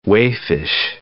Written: waifish

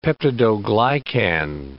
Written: peptidoglycan

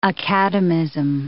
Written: academism

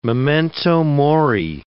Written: memento mori

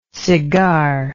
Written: cigarlike

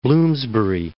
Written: Bloomsbury

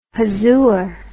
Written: huzoor

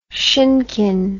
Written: shinkin

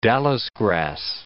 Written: Dallis grass

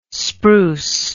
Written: spruce

